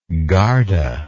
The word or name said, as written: garda